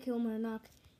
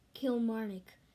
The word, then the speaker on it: Kilmarnock: I made a mistake pronouncing Kilmarnock. I pronounced it Kilmarnock. It's pronounced Kilmarnock.